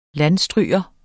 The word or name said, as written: landstryger